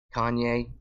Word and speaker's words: Kanye: How are you saying his name right now? – How am I? – No, what were you calling him right now? – Conway. – Conway West.